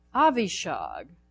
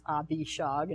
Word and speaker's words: Avishag: Avishag. How you pronounce it, even though it's Abishag in English.